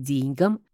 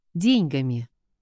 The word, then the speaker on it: деньгам: Разумно же, что тогда должно быть деньгам, о деньгах – деньгами. В дореволюционных языковых правилах и нормах так и было. Сегодня же правильно считается форма деньгам, деньгами, о деньгах, поскольку именно такое ударение стало преобладать в речевой практике.